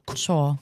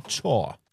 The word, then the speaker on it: Chore: Und dann hatte ich dabei Musik aus Zürich, Odd Beholder, like a, wie wird es ausgesprochen? – Chore. – Chore.